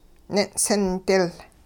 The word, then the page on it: nin'sindil: Hupa